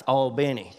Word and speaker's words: Albany: And I said that right, it's Albany.